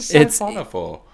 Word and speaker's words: siphonophore: So a siphon, S-I-P-H-O-N. – Siphonophore, yeah. – O, I got it. – P-H-O-R-E.